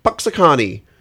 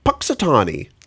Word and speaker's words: Punxsutawney: He's a very full of himself Pennsylvania weatherman who gets trapped, stuck having to go to Punxsutawney. – I don't think you said that right. – No, let's try that again. – Okay. – Who gets stuck going to Punxsutawney, Pennsylvania.